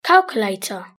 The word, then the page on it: calculator: British English